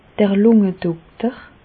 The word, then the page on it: d'r Lùngedokter: Bas Rhin; Reichshoffen